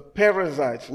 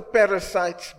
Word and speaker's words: Perizzites: The Perizzites, not the Perizzites, but the Perizzites, the Girgashites, the Amorites, and the Jebusites, whatever you call them names.